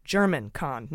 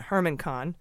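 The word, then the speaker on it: Germán Khan: It's been pointed out to me by someone in the know that it's pronounced German Kahn, not Herman Kahn.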